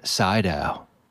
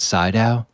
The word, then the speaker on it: Sydow: This stars Ellen Burstyn, Jason Miller, Linda Blair, and Max von Sydow. Did I pronounce that right? Sydow? – I think you're right, yeah.